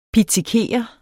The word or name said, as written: pizzikere